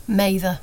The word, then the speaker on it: Mather: Giselle, is your surname got a long A or a short A? – Mather.